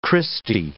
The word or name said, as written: Christy